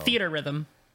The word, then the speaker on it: Theatrhythm: Yeah, I don't know how to pronounce it still. – Theater rhythm. – It's theater rhythm? – Yes. – Theater rhythm. – Yeah. Okay. It's just like how it looks.